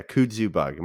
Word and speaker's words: kudzu: Can, can you see that? K D K U D Z U. – Yeah, yeah, kudzu bug. Am I saying it right? – Sure.